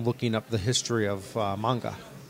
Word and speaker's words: manga: It's spelled manga, so I have to remember that it's not pronounced that way. – Right, we don't put the American A on there.